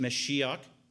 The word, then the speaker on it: Mashiach: Messiah is more of the Hebrew pronunciation, Mashiach, the anointed one.